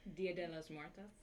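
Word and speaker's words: Día de los Muertos: They're going to be doing a De La Masa, Muertos, M-U-E-R-T-O-S. Did I pronounce that right? Someone give me a hint. Go ahead. How would you pronounce it? – Dia de los Muertos.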